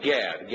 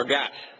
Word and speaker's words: Gad: Or Gat. Gat, as his name would be, probably be pronounced.